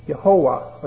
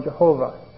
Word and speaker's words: Jehovah: And so the pronunciation became Yehovah or Yehovah were formed. And Yehovah provides the basis for the Latinized form Jehovah that we use today. Since the original pronunciation of God's name has been lost, we continue to use the familiar name Jehovah.